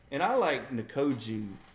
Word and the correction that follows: Njoku: Did I say his name right? David Nkoju from Cleveland. – Oh, Njoku. – Njoku, yeah.